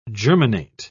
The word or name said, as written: germinate